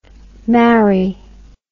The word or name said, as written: marri